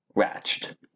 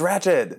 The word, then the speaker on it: Ratched: Okay, y'all, so we're diving in, we're taking a sip, because whoo, have you seen Ratched? – It's Ratched.